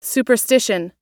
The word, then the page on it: superstition: American English